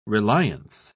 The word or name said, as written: reliance